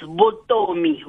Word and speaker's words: Vuttomi: It's Vuttomi. – Vuttomi. – Like a bee. Vuttomi. – Oh, Vuttomi.